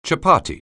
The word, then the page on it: chapat(t): anglais